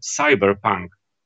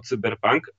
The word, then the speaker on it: cyberpunk: E, bo nie wiem, czy, czy pamiętasz, była taka kampania reklamowa gry komputerowej, o której mówimy, gdzie e, twórcy tej reklamy wymyślili sobie, że będą to wymawiać jako cyberpunk. Co jest kuriozalne, dziwne i zupełnie nie rozumiem dlaczego. I jak najbardziej w języku polskim mamy ten przedrostek cyber, prawda? Bo mamy cybernetykę chociażby, więc tutaj wątpliwości nie ma, że prawidłowa wymowa to, to cyberpunk.